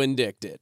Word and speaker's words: indicted: He got so indicted. – Wait a minute. That's how it's spelled. – It is how it's spelled. The C is silent.